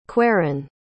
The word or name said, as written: queran